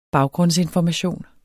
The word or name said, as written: baggrundsinformation